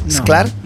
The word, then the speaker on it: Sclar: No, Sclar. Y tampoco lo digo con S, con J. – Juan Sclar, como mosca, que se escribe con J. – Aspiramos la S antes de las consonantes oclusivas en español. – Muy bien.